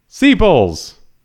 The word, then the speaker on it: sepals: Sepals. Sepals. Sepals. No, I'm not. You're pronouncing them wrong. How do you pronounce them? Sepals.